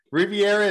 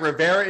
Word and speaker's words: Rivera: Always, some people say Rivera. I've heard someone say Rivera before, and I've been thrown off ever since. – Rivera is incorrect. Rivera is correct.